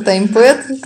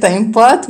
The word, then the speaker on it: TimePad: Я произношу таймпэд, кто-то из коллег таймпад.